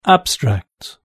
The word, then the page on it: abstract: British English